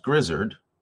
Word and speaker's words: Grizzard: It's not pronounced Grizzard.